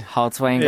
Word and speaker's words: Haltiwanger: Did I say that right? – You did. – Haltzwanger. – It has been butchered so many different ways, so it's okay.